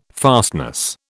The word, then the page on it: fastness: British English